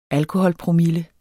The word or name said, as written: alkoholpromille